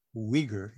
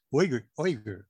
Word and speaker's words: Uyghur: You're not supposed to say Uyghur. It's Uyghur. It's almost O-I-G-U-R.